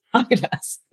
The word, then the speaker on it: Häagen-Dazs: Is that how you pronounce it? Häagen-Dazs? – Häagen-Dazs. – Is that right? – Yeah.